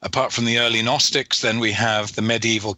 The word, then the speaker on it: Gnostics: You mentioned the Gnostics, which is spelled with a G at the beginning for those that have never heard the word.